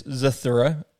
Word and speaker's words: Zathura: It's Zathura.